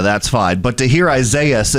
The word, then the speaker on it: Isaias: So Isaias is the correct pronunciation of it.